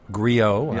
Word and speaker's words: griot: Griot. – Yeah. I'm sorry, I mispronounced that. – It's a French word, but it urban. The griot is from African from the African tradition.